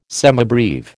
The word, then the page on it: semibreve: American English